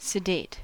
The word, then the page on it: sedate: American English